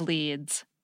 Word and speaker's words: lede: Same spelling, L-E-A-D, but a different pronunciation and, of course, a different meaning altogether. So, for clarity, the first paragraph of a news story is the lead, spelled L-E-D-E.